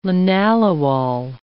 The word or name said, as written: linalool